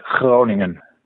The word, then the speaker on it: Groningen: And my working place is Breda, and research is performed at Groningen.